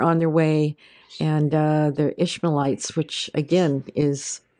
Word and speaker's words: Ishmaelites: Then they lifted their eyes and looked, and there just so happened was a company of Ishmaelites.